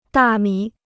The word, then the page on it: 大米: dà mǐ